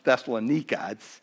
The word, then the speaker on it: Thessalonica: the city, first of all, of Thessalonica, or properly pronounced Thessalonica. It's the way it's pronounced, so I'll probably float between those two.